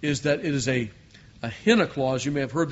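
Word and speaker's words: Henna (ἵνα): And in verse 14, we have what we call a henna clause. Henna. I, Yota, with a rough breathing mark, Nun, Alpha. Henna is pronounced, and it means in order that.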